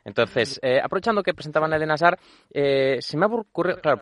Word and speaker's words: Eden Hazard: Él es belga, se pronuncia, es Eden Hazard. – Hazard. – Esta es la forma correcta de decirlo. – Además, a mí me han comentado algún compañero que tampoco se pronuncia ni la H, por supuesto, ni la D final, casi.